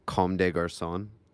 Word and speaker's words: Comme des Garçons: How do you say CDG? – Comme des Garçons.